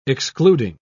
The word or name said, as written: excluding